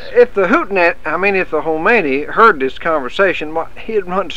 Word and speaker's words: Khomeini: That country over there that got rid of their Shah and put in a hootenanny. – Sam, that's pronounced Khomeini, not hootenanny, and that's Iran, not Iran.